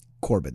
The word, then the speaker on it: Corbitt: Corbitt.